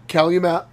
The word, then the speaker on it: Calumet: I think it's a Michigan City, I believe it's pronounced. – Calumet. Yep.